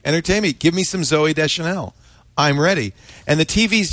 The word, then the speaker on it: Zooey Deschanel: Let me just see if Zooey Deschanel...